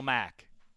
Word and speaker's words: Mack: Khalil Mack. His name rhymes with sack.